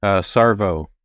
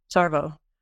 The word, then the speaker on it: Savereau: Savereau, is that how it's pronounced in the book? Because boy, does he like to give us names that just really need consonants. – Sarvo. – Sarvo. Okay, it's all vowels.